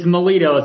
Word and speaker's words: Milito: It's Milito. It's like military Milito with an O instead of an IA.